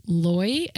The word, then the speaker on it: Loi: And so this woman's name is Rita, R-E-E-T-A. And I think her last name is pronounced Loy, L-O-I, and she goes by Loyal, L-O-I-A-L.